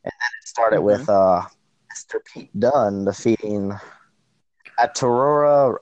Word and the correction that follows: Arturo Ruiz: Oh, Arturo Ruiz? – Yes, yes.